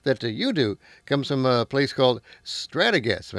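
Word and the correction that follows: Strategas: if I'm saying that right. What is StratoGas, and how do they help you? – Yeah, Strategis, and it's actually a Baird company.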